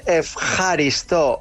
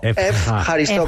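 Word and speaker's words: efjaristo (ευχαριστώ): Se dice efjaristo, efjaristo.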